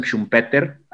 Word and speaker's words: Schumpeter: El mismo Joseph Schumpeter, así se, así se escribe y así se pronuncia, ¿eh? no crean que soy este, pocho. se escribe Schumpeter y así se pronuncia porque el señor es austriaco.